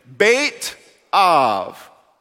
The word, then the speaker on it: Beit Av: Okay, Beit, and then the B in Av is pronounced with a V. Okay, you ready? Beit Av.